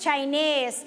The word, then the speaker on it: Chinese: Okay, your answer is Chinese. Okay, can you say it together? Spell it together. 1, 2, 3. – C-H-I-N-E-S-E. – Okay, say this word together. คำนี้อ่านว่า Chinese. – เราจะเน้นที่เสียง Nese นั่นเอง One more time, Chinese.